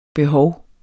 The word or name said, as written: behov